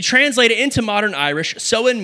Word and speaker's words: Samhain: You spell it S-A-M-H-A-I-N, but you pronounce it Samhain in Irish.